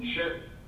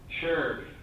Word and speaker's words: Schurg: Sure. Sure. It's like saying sure with a G at the end.